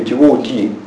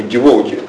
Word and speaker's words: devotee: Интересно, что ударение в нем ставилось на последний слог. Слово пришло, вероятно, из французского, и говорили devotee. Я проверял в старых словарях, действительно, везде, именно в старых словарях, devotee, ударение на последнем слоге. Шрила Прабхупада стал использовать это слово, такое забытое, устаревшее, для обозначения преданного Господа. И стал ставить ударение, причем на втором слоге, devotee.